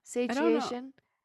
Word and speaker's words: satiation: Semantic saturation. Semantic saturation. Semantic saturation. Am I saying it wrong? Satiation. – Satiation. – Satiation. – Google say it. – Semantic satiation.